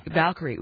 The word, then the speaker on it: Valkyrie: Valkyrie.